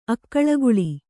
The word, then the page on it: ಅಕುಳಿಗೊಳ್: Kannada